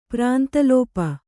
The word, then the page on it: ಪ್ರಾಂತಲೋಪ: kannada